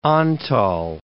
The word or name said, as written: Antall